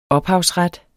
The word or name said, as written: ophavsret